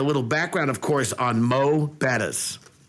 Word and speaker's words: Mo'Bettahs: So appreciate you guys saying it right, by the way. Let me start there. So many people love to start off and say, oh, more betters. We're going to go to more betters.